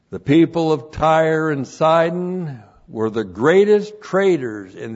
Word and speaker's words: Sidon: And then we have with Tyre, Sidon. T-S long I-D long O-N. Sidon.